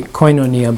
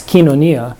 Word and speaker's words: koinonia: sometimes people say koinonia, but that's in Greek pronunciation, it's kinonia.